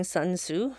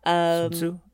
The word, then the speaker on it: Sun Tzu: Okay, anyway, I loved Saru quoting Sun Tzu. I'm saying that wrong. – Sun Tzu, yeah.